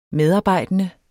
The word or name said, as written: medarbejdende